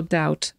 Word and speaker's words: doubt: Słowo doubt wymawiamy bez litery B, ignorujemy ją, tak jakby jej w ogóle nie było.